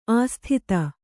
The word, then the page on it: ಅಸಾಧುತೆ: asādhute